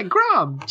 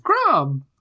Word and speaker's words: Grahm: Yeah, Grom. G-R-A-H-M. Grom. – Okay. Okay. – Like Graham.